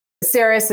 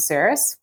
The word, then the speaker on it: Saris: And first off, thanks for pronouncing the company name correctly, or with at least the correct Midwest accent. Saris is actually the combination of our founders' names, Chris and Sarah.